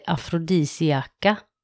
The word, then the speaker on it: afrodisiaka: Det var väldigt konstigt, alltså enligt svensk ordbok eller något så hette det afrodisiaka.